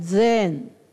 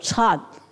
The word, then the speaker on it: 禅: 那我这里要跟各位说，我们有的时候看国际学术禅学会议，他们就把禅翻成 Zen Z-E-N，我说错了， Zen 是日文，日本的发音，我说要翻成什么 Chan C-H-A-N，那才是中文啊。